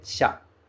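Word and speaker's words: xá (車): A pronúncia curtinha dele é xá. Xá.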